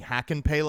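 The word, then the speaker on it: Hakanpää: And then also we've got a texter unsigned texting, and we're just going to let Drance get away with pronouncing hack and pay like that. I'm the guy doing it right. – Is it pay? It's not pa? – It's pay.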